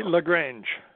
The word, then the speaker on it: LaGrange: Yeah, Gary LaGrange.